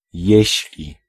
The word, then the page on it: om: /ɔm/